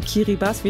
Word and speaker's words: Kiribati: Und wir haben eine Geschichte aus Kiribati, korrekt ausgesprochen Kiribati, wie wir ja beide gelernt haben heute.